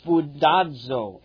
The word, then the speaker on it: spoudazo: It is the Greek word spoudazo, S-P-O-O-D-A-Z. Well, actually, it's S-P-O-U-D-A-Z-O with the emphasis on the O.